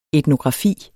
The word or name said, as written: etnografi